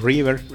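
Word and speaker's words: Reaver: Otro que, es otra herramienta que este que sí probé, que se llama, eh, se escribe R-E-A-V-E-R, e e o River.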